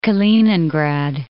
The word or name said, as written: Kaliningrad